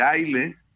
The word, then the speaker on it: Islay: Y luego nos vamos a, los whiskies de, de Aile, o Islay, así se escribe, que son pocas destilerías.